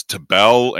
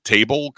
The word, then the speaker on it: Tabell: If you don't know that Ethan Tabell's name is Tabell and you pronounce it table because it looks like that, this is how you learn.